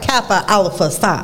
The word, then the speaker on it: Kappa Alpha Psi: Okay, Kappa Alpha Psi. Let me say that right. Kappa Alpha Psi. – There you go.